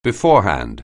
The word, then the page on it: beforehand: anglais